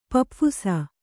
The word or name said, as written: ಪಪ್ಫುಸ